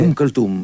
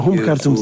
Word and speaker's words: Umm Kulthum: Esse músico que eu não vou usar pronunciar, que tu disseste anteriormente, é Umm Kulthum, é uma cantora egípcia. – Ah, Umm Kulthum, sim.